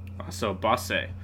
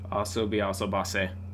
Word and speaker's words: Asobi Asobase: Another anime that recently came out that we've been enjoying that does that really well as well is Asobi Asobe. – Asobase. – Asobase. – We've been pronouncing it wrong for like three episodes. – Yeah? – Yes. Asobi Asobase.